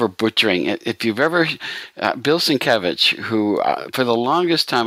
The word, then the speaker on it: Sienkiewicz: and on the big letters above this booth it said Sin in small letters, and it had Kev in great big letters. Itch on the end. And I went, oh, that's how you pronounce it. Sienkiewicz.